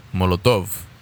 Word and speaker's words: Molotov: Molotov? – Molotov.